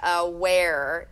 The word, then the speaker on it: Awair: It's spelled AWAIR, Aware.